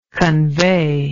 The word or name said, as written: conveyable